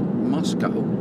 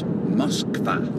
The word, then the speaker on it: Moskva: See, it's not Moscow, M-O-S-C-O-W. No, no, it's Moskva. Moskva.